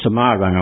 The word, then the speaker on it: Semarang: and to the town of Semarang, or Semarang, I was told the other day is correct pronunciation.